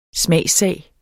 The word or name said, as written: smagssag